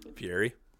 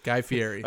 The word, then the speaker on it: Fieri: Fieri. – Yeah. You don't do the... – Fieri. – Yeah. Guy Fieri.